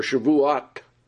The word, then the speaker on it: Shavuot: And he said, then how come the rabbis start counting to Shavuah or Shavuot? That's how they pronounce it for Pentecost.